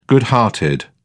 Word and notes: good hearted: British English

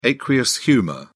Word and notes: aqueous humour: British English

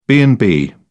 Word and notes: b and b: British English

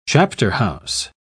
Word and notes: chapterhouse: British English

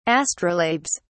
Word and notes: astrolabes: British English

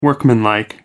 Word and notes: workmanlike: American English

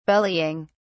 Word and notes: bellying: British English

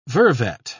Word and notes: vervet: British English

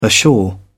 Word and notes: ashore: British English

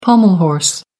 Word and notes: pommel horse: American English

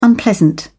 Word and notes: unpleasant: British English

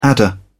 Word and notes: adder: British English

